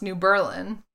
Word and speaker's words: New Berlin: That's how, there's a town in Wisconsin that to this day is pronounced New Berlin. And it's because they didn't want to be Berlin during the war.